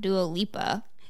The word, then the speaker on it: Dua Lipa: And don't ever forget that Anwar Hadid also brought Dua Lipa.